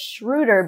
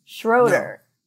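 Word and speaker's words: Schröder: I was saying Dennis Schroeder because that's how you would pronounce it in German, but he pronounces it Schroeder.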